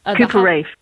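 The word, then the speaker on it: Cooper Raiff: There's a film called Cha-Cha Real Smooth, directed by an up-and-coming director, Cooper Reif, I think is how you say his name. – Cooper Reif. – Thank you.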